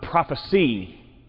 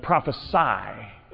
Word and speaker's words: prophesy: the word they said was not prophecy, but prophesy. Spelled the same way, but prophecy is the noun, prophesy is the verb and the command. And yes, every year, crowd tends to say prophecy.